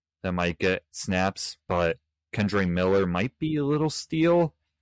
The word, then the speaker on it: Kendre Miller: Kendre Miller, that's how you say it, from the New Orleans Saints.